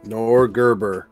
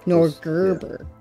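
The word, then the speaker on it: Norgorber: Norgerber. Sorry, I said it wrong. – Norgerber. – Like a Nordic Gerber baby.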